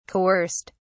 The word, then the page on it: coerced: British English